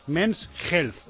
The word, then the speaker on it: Men's Health: Men's Health, o Hilt, o Holt. ¿Cómo se dice? ¿cómo se dice esto? Health, Men's Health, es que es la políglota.